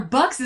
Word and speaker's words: Bux: Bastian Balthazar Bux is an awesome name.